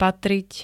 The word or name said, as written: patriť